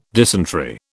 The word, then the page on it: dysentery: British English